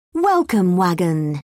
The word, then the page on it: welcome wagon: British English